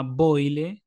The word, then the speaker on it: Boyle: Y acá Susan, la original, se llama Boyle con Y, L, E. – Claro, claro, sí. E, es, como. – Se pronuncia igual, pero se escribe distinto.